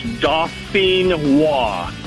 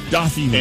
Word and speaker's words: Dauphinois: All right, so pronounce that for me one more time so I don't sound like a complete idiot when I order them. – No problem. It's, like I said, it's French. It's Dauphinois.